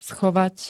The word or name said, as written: schovať